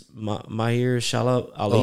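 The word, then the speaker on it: Mahershala Ali: Amir something? – No, it's Mahir Shalab Ali.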